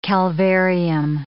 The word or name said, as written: calvarium